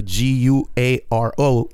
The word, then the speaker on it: guaro: It's it's a short word for guaro, right? So it's spelled the G U A R O instead of agua ardiente. It's mm. guaro.